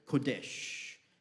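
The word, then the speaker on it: kodesh (קֹדֶשׁ): Well, it's from the Hebrew word kodesh. It's spelt with a Q, kodesh, and it means to be cut off, to separate, to be set apart.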